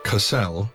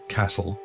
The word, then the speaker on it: Kassel: Kassel, never Castle, a common mispronunciation that also irritates the natives, is the resort world of the Gauss system, the ultimate destination for hundreds of millions of human tourists every year.